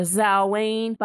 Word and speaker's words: Samhain: And it's Samhain, not Samhain.